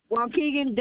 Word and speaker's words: Waukegan: Waukegan, WAUKEGAN.